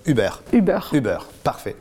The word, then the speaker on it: Uber: Mais tu vas être un puriste, en fait, Uber, ça vient de l'allemand, ça se prononce Uber, donc on dit Uber.